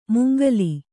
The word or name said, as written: ಮಂಗಲ